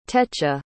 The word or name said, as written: techa